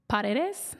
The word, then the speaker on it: Paredes: Uh, and also, I'm going to butcher his last name, but the third baseman for the Rays, Isaac Paradis. – Paredes. – Paredes. – Paredes. – Paredes. A little stink on the D. More Espanol.